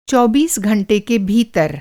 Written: चौबीस

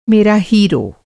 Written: हीरो

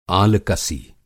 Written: आलकसी

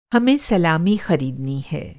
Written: सलामी

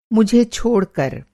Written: छोड़कर

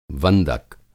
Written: वंदक

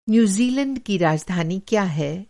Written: न्यूज़ीलैंड

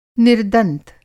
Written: निर्दंत

